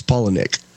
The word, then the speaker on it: Palahniuk: Most people have trouble with it, but it's, you take the name Paula and you take the name Nick. That's how you pronounce it. It's Palahniuk.